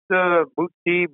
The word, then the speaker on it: Boutte: Yep, Calvin Boutte. My last okay. – name. Yeah. Well, it's pronounced Boutte. It depends where you're standing, you know, what city okay. you're in. But it's French.